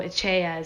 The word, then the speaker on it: etxea: So Echea is house. So when you see ET and they use the TX, right? So when you see ETXEA, the TX is pronounced CH.